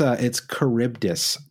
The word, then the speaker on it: Charybdis: And by the way, it's Charybdis, not Charybdis, Brent. I forgot that the Greek, it's a hard C, like a K kind of sound.